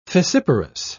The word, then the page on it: fissiparous: British English